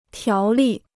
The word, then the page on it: 条例: tiáo lì